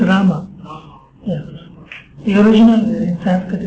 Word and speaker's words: Rama: Yes, when you say Rama, don't say Ma. That's Rama Devi, Rama. Rama means goddess of fortune. Yes, it is a syllable. It's 32 syllables. It's 16 words and 32 syllables. Like Krishna, 2 syllables. Rama, 2 syllables.